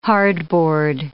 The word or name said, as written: hardboard